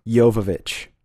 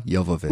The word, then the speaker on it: Jovovich: It's Mila Jovovich. – Mila Jovovich.